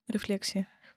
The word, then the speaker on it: рефлексии: рефлексии. Рефлексии. – Да. – да, тоже неправильно ставил ударение до этого постоянно.